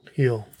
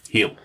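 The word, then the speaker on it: Hiel: I want to make sure I get his name right because it's H-I-E-L is his last name. Joe Heel. – Heel. – Yeah.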